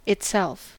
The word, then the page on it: itself: American English